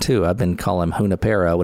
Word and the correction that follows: Junipero: and it's Junipero, emphasis on the I. – Yeah, in the Spanish, the accent is on the I.